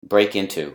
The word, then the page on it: break into: phrasal verb